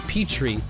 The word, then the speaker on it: Petteri: My first name is pronounced Petrie, but it's not spelt like that. – Okay, I don't know. I don't know the answer. – Okay, the last name I'm just going to give it to you because it's really hard. I couldn't think of an easier clue here. Nokalainen. – Oh, Petri Nokalainen. Okay. – Yeah, but it's spelled like Petteri, P-E-T-T-E-R-I.